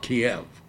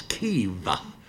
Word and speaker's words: Kyiv: For decades, I've thought Kiev was the capital of Ukraine. No, it's Kyiv. It's not a two-syllable word now. It's a one-syllable word, and it still bounces back and forth depending on who's saying it.